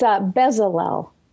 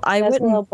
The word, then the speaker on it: Bezalel: It's Bezalel. – Bezalel. – Bezalel Books.